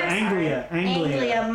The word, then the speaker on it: Anglia: Wait, there's Angelia. No, I'm sorry. Anglia.